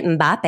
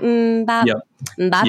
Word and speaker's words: Mbappe: Did I say that right? Mbappe. Mm-hmm. It sounds like a Hanson song, you know, Mbappe.